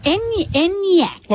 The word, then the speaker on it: Enyaq: Elroq and Enyaq. – Enyaq. – Like Enya from the 80s and 90s.